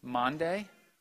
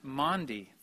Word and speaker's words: Maundy: I once thought it was pronounced Monday, and that's convenient because it rhymes with Thursday. Monday, Thursday. It's actually pronounced Maundy Thursday, I learned.